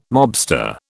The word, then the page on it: mobster: British English